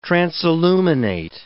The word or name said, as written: transilluminate